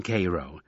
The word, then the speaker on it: Cairo: Cairo? – That's how they pronounce the one in Egypt, but the one in Little Egypt they call that one Cairo.